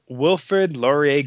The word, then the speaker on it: Wilfrid Laurier: But for me, at number five is Wilfrid Laurier Golden Hawks. Did I pronounce that right? You did.